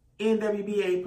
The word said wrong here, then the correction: WNBA: player. Did I say that right? It was a WNBA. Yeah, WNBA player. I said that right now?